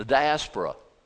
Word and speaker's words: diaspora: The diaspora. The diaspora, I mean. I put the syllable in the wrong, the emphasis in the wrong syllable. The diaspora.